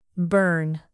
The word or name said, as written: bern